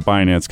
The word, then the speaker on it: Binance: So CZ, and he gets the last name Binance because it just sounds cool. – Because it rhymes with finance, right? – You got it.